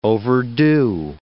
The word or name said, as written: overdo